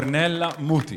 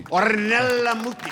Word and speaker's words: Ornella Muti: I think it's Ornella Muti. I'm sorry, wow. Could you say the name properly, please? I'm sorry. – Ornella Muti. – Ornella Muti. Of course.